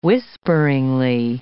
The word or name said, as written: whisperingly